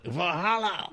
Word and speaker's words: Valhalla: Oh, someone just told me that I bought a Valhalla as well. Am I saying that right? I feel like I'm saying that wrong. – That's sacrilegious. You can't say it like that. – Valhalla.